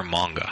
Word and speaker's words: manga: Is it manga or manga? – I say manga, but I think either pronunciation is correct.